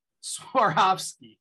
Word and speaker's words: Swarovski: How do you pronounce that? Sarkozy? – Swarovski.